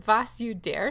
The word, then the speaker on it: Vasuder: And that's how it's spelled too. Was, like V-A-S-U-D-E-R. Was You Dare, Charlie? – Is it a question?